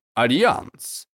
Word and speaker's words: Альянс: Можно произносить на французский манер. Альянс.